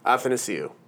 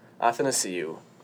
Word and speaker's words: Athanasiou: Athanasiou. – Very nice. – Andreas. – Andreas Athanasiou.